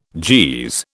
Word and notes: Jeez: British English

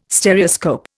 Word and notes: Stereoscope: Indian

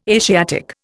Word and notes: Asiatic: Indian

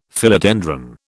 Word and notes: Philodendron: British English

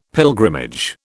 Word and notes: pilgrimage: British English

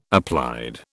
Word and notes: applied: British English

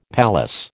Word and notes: palace: American English

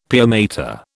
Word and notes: pia mater: British English

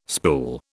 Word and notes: spool: British English

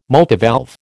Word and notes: multivalve: American English

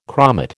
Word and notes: chromate: American English

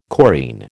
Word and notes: chorine: American English

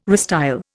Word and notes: restyle: Indian

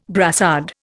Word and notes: brassard: Indian

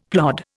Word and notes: plod: Indian

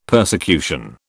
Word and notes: persecution: British English

